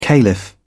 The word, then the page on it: caliph: British English